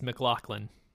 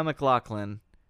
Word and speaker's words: MacLachlan: I just watched the- I just watched a YouTube video on how to pronounce his name and it's MacLachlan.